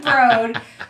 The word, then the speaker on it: Broad: Spelled Broad, pronounced Broad. Mm-hmm. It's like a Houston, Houston thing.